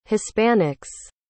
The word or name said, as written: hispanics